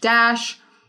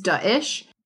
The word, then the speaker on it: Daesh: So a lot of people are saying Daesh, Dash. The correct pronunciation is Daesh.